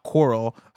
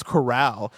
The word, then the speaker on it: corral: Portland Kev says, Tim and Blessing pronounced the name Outlaw Coral, like the word coral as in the ocean organisms, coral reefs. The word should be pronounced corral, yeah. – as in the country style containment.